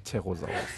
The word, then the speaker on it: Pterosaurus: Pterasaurus. Pterasus. – Irgendwie so. – Aber noch mit einem P davor. – Pterosaurus. – Genau.